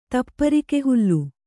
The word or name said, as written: ತಪ್ಪರಿಕೆಹುಲ್ಲು